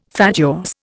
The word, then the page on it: fatuous: Indian